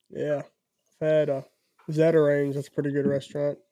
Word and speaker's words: Zatarain's: Bro, yeah, it's Zatarain's.